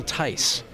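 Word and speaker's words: Theiss: And one day I called information and got the number for Paramount Pictures, called the studio and asked for, I pronounced it Bill Thies at the time. – Right. – And they corrected me. They said, no, it's Bill Tice. – I thought it was Thies. – Yeah, it's Tice.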